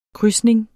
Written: krydsning

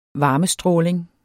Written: varmestråling

